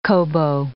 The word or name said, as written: kobo